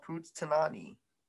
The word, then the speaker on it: Punxsutawney: Yeah, it's called Punxsutawney. Punxsutawney. – Did you look it up? – No, I didn't. – I'm gonna look it up. – Saying it out. Punxsutawney.